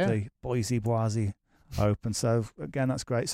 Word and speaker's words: Boise: Is it Boise Open or Boise? – Yes, Boise. – Boise. – Yes, that's Boise.